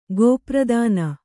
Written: ಗೋಪ್ರದಾನ

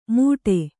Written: ಮೂಡೆ

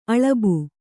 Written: ಅಲಬು

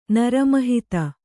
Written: ನರಮಹಿತ

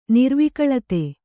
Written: ನಿರ್ವಿಕಳತೆ

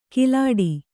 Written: ಕಲ್ಲೆಡೆ

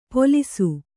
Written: ಪೋಲೀಸು

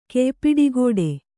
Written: ಕೆಯ್ಪಿಡಿಗೋಡೆ

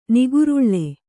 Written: ನಿಗರಳ

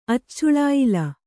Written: ಅಚ್ಚುಳಾಯಿಲ

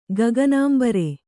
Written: ಗಗನಾಂಬರೆ

